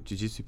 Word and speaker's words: jiu-jitsu: They actually just, there's a jiu-jitsu place. Am I saying that right, by the way? – Jiu-jitsu? – Yeah, okay, cool.